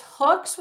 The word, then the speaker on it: Hooks: Well, it's Hooks with an S, my husband's name, which happens to rhyme with book that has no S, event.